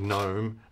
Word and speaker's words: gnome: Like gnome. Gnome is like, it's a silent G, and no is a silent K.